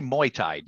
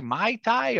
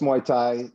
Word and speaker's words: Muay Thai: Do you say Mai Thai, or how do you say it? – No, it's Muay Thai. Okay. I have to correct my mother sometimes. It's like, Mom, it's not a cocktail. It's not Mai Thais.